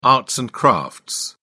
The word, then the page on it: arts and crafts: British English